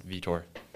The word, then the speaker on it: Vietor: And also, my last name's pronounced Vietor.